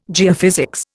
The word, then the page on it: geophysics: Indian